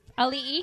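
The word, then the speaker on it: Auli'i: Ali'i.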